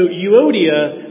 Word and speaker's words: Euodia: All right, so Euodia, this is pretty widely agreed upon.